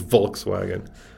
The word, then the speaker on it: Volkswagen: So a W in German, a W is a V, right? So it is Volkswagen.